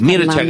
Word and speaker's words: Mircea: Lo dije bien que estás con el rumano vos. – Mircha Cartarescu. Sí, lo dijiste perfecto.